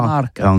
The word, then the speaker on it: marcat: Eh bien, dis ça à tous, mais le mercat de l'Uni ou le mercat des rebelles, mais en gascogne toulousain, c'est prononcé sur les marcat, avec un A, marcat.